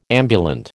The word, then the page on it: ambulant: American English